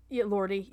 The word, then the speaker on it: Elordi: Jake Elordi. I don't know if that's how you say his name. Oh, yes. Elordi. – Elordi, yeah.